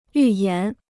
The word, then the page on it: 预言: yù yán